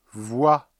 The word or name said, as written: voix